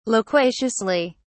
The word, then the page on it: loquaciously: British English